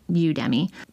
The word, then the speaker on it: Udemy: D-E-M, Why? Udemy, like Academy, but Udemy.